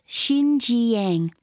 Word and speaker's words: Xinjiang: It runs from the Yellow Sea in the east, curves around the north of Beijing, and ends up in Xinjiang, pronounced Xinjiang province, far in the west.